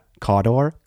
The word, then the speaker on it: Cawdor: And how do you pronounce that? – Cawdor? – Cawdor! There it is.